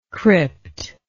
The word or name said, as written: cryptal